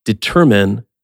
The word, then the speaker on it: determine: Second syllable stress, determine.